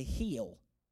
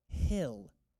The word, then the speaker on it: hill: Some of you say heel. I want you to know that is not right. Jesus does not approve of that. The word is pronounced hill, the hill country of Judea, where she entered Zechariah's home and greeted Elizabeth.